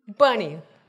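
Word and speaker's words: Bunni: And someone confirmed to me that is actually how it is pronounced in the Hebrew, Bunny.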